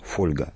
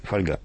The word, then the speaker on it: фольга: Такой долгое время в словарях единственно правильным считалось ударение «фольга», при том, что лично я не знаю ни одного человека, который бы так произносил это слово. – Я знаю только, что в Серебряном веке это слово рифмовали со словом «Ольга».